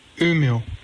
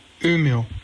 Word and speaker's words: Umeå: And next comes the pronunciation of the town name. – Umeå. Umeå. – So Umeå. That's probably still wrong, but a lot better than I would have done without MW's help.